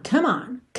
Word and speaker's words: come on: Come on, atenção, como o E final de come não é pronunciado, então você vai do M direto pro on. Come on, come on.